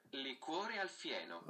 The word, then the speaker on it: Liquore al fieno: weil italienisch spricht man es so aus. – Liquore al fieno.